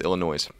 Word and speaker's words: Illinois: You're saying the state's name wrong. It's Illinois.